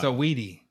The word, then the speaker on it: Saweetie: Saweetie. That's how you say her name.